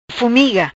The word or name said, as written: Fumiga